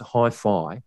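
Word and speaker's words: HiPhi: And it is, in fact, pronounced Hi-Fi.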